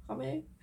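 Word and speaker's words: Rommé: Ich bin so süchtig nach Rommel. Spricht man das so aus? – Rommel? – Ich kenne mit so einem Apostroph. – Und E mit einem Apostroph, ja. Rommel.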